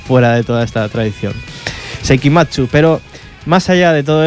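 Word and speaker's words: Seikima II: Por ejemplo, la que vamos a escuchar ahora, una banda llamada Seikima 2. Seikima 2, que en realidad se pronuncia Seikimatsu. Seikimatsu, esa es la manera de pronunciarlo en japonés.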